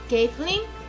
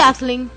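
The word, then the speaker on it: Kathlen: Es Kathleen.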